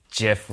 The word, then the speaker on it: GIF: The record, it's GIF with a soft G.